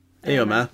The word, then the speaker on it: Éomer: But Éomer is onto him. Is that how you say it? I don't know. – Éomer, yes.